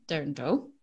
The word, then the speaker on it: dirndl: The dirndl? – Dirndl.